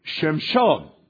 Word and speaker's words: Shimshon: His name, Samson, is actually Shimshon.